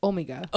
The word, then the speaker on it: Omega: The brains of the three Doctors discover the incidents are caused by Omega, which they pronounce Omega. – Omega. – Omega. – Omega. – Omega, but it's spelled Omega, like the Greek symbol.